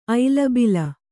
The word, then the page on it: ಐಲಬಿಲ: ailabila